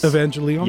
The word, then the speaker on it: Evangelion: Evangelion.